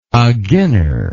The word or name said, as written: aginner